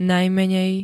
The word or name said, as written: najmenej